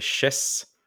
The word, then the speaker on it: Shez: Eh, så man spelar som en ny huvudkaraktär då som heter Chess. S-H-E-Z. Jag har för mig det uttalas så i alla fall.